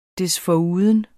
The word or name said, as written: desforuden